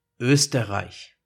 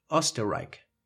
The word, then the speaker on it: Österreich: So the Ö probably gets replaced with O, and the H with a K sound, because that's the pronunciation in some English words with the combination CH, like in chemistry. So instead of saying Österreich, they will say Österreich.